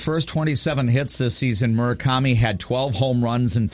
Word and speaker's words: Murakami: Yeah, I don't know if his first name is Munetaka, but I believe his last name is pronounced Murakami. – Murakami.